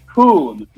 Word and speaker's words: Kuhn: They're not saying boo, they're saying coon. Akcentując u w nazwisku tego zawodnika,